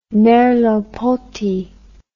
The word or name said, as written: merleau ponty